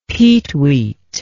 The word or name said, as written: peetweet